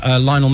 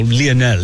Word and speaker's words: Lionel: It is pronounced Lionel. Loads of football commentators call Lionel. – Lionel. – But it's actually Lionel, because his mum was a big fan of Lionel Richie.